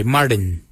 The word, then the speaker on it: Marrdiny: Kujo is spelled G-O-O-D-J-A-L, kujo. For 3, we say marin. Marin is 3. Marin is spelled M-A-R-R-D-I-N-Y, marin.